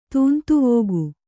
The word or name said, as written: ತೂಂತುವೋಗು